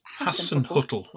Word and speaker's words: Hasenhüttl: Last time we spoke to our listeners, we asked them, I can't pronounce this. Is it Ralph Hasenhüttl, I say, yeah. – Hasenhüttl, I do too.